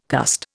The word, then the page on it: cussed: Indian